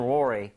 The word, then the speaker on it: worry: 큰 건 아니지만 이것도 발음할 때 너무 worry처럼, real처럼 발음하지 마시고요. 아시죠? 입 모양 모으고 혀 코어 부분을 당긴다는 느낌. So worry, re라고 돼야지. re 이렇게 하시면 안 됩니다.